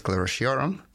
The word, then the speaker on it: sclerotiorum: It's caused by a fungus called Sclerotinia sclerotiorum.